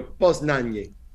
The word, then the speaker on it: Poznań: Hi, I'm Desmond Child and you are listening to a solid week of my biggest hits on Radio Poznań.